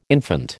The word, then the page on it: infant: American English